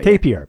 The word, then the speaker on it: tapir: The very first video on the channel, and excuse me if I mispronounce it, Tapir. – That's right. – Tapir, yeah.